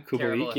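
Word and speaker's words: Kubalík: I think it's pronounced Kubalik, but I'm Kubalik. Yeah, I knew I was butchering that.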